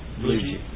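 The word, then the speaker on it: Bluege: Blugie. – Thank you. I've wondered how to pronounce his name, and I didn't know until this evening.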